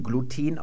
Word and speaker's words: Gluten: Dann wird das, wenn man ins Lateinische sich vorarbeitet, zu Gluten das wir heute im Deutschen Gluten aussprechen. Das hat mit der anorganischen Chemie zu tun, dass man da hinten betont, also auch Ethylen und nicht Ethylen sagt.